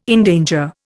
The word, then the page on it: endanger: Indian